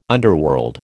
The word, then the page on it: underworld: American English